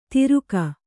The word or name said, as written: ತಿರುಕ